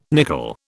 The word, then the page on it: nickel: British English